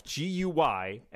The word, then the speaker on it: Guymelef: You spell it again? – Guy Melf, Guymelef. – Melef? – Yeah. The second E is... – Oh, so it's like Guy Melef. Well, that changes everything.